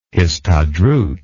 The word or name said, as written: histadrut